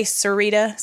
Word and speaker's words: Sarita: people say Sarita, so it sounds like it should be.